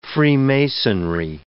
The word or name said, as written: freemasonry